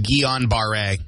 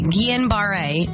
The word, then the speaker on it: Guillain-Barré: Now, it's Guillain. – No. – Guillain. – Nope. I'll help you. It's pronounced Guillain-Barré. – Okay. Guillain-Barré syndrome, a lethal condition in around 1 of 20 patients.